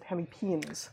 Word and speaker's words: hemipenes: I would have said penises, but as a herpetologist, that is to say someone who studies the creepy crawlies that include the amphibians and reptiles, I know that you're supposed to pronounce it hemipenes.